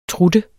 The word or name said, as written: trutte